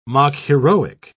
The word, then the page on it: mock-heroic: British English